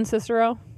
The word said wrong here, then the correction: Sincero: It's pronounced Sincero.